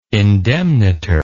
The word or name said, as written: indemnitor